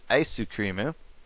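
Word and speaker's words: aisukrimu (アイスクリーム): Ice cream is sometimes pronounced more correctly as aisukrimu, but you mainly hear older people pronounce it that way.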